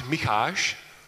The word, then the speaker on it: Micháš: So if I say it correctly in Czech, it's Micháš.